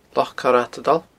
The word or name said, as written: Loch Coire a Tadail